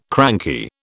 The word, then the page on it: cranky: British English